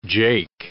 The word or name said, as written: jake